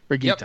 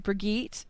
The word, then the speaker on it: Brigitte: I'm going to say it because I keep wanting to put a soft G in it, and that's not how it's said. How do we say her name? – I think it's Brigitta. – Brigitta, yep.